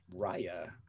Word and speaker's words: Raya: Raya, like Raya and the Last Dragon, which R-A-Y-A.